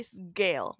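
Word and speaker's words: Sgaeyl: And I know you say it differently, but I'm going to go Sagal. I know it's like Sagal, but this is why I said it the first time. – I just say Sagal. Sagal. Gale with an S.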